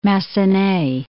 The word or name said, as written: Massenet